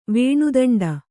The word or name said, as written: ವೇಣುದಂಡ